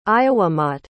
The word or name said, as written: ayawamat